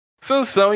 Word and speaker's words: Sansão: É, eu não tô muito por dentro da Bíblia, cara, mas é Sansão, né? É isso. – É, achei isso mesmo. – Aí você tem que pronunciar San com N, né? E São com tio. Ah, ótimo. – Sansão, então, cara, eu vou de 1-1 pra esse jogo aí.